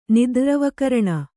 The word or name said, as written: ನಿದ್ರ್ರವಕರಣ